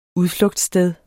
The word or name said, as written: udflugtssted